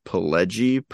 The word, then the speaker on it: Pileggi: It's got a really fun I'm going to try not to say it wrong, Mitch Pileggi performance.